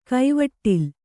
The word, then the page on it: ಕೈವಟ್ಟಲ್: kaivaṭṭal